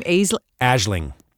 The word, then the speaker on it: Aisling: Aisling.